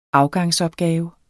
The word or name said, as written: afgangsopgave